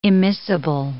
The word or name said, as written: immiscible